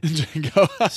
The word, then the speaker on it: Django: Django. – So, but the one on a silent D.